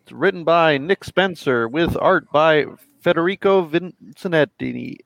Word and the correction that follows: Vicentini: I, I say that wrong every time. I'm sorry. – Vicentini. But how's the anchor? Vicentini.